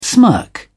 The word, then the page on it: smirk: British English